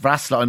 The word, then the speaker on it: Wrocław: And yeah, Wrocław, pronounced Wrocław in Poland, it used to be Breslau before the war, and then it changed over.